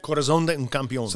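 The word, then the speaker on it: Corazón de un Campeón: Corazon de un Campeón.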